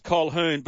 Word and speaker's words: Colquhoun: Big man, does it annoy you that Jesse Colquhoun you pronounce it Colquhoun, but it's spelt Colquhoun.